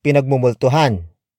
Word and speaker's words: pinagmumultuhan: This lesson is brought to you by the Ghost Town Podcast and the Tagalog word pinagmumultuhan.